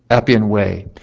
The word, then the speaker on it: Appian Way: Appian Way.